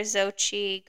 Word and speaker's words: Xochitl: Okay, I think that Jason Mraz or Xochitl Gomez will win. I'm saying it now. – That's how you say her name.